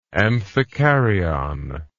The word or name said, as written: amphikaryon